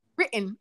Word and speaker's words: written: Yeah, and they said, they've noticed that I don't even say written. And I said, that's TT silent. Yeah. – Written.